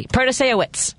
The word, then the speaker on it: Protasiewicz: and just do it phonetically. Protaseowicz.